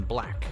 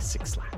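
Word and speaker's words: 6lack: Since then, she's collaborated with acts like Eminem a few times, and her album actually features another song with that Detroit rapper, and then Black, although he's spelled with a six, so it looks like Six Lack.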